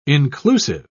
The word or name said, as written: inclusive